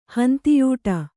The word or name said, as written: ಹಿಂದೆಯಾಡು